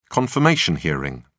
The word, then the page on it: confirmation hearing: British English